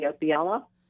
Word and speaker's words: Opiela: It's author Kathy Opiella.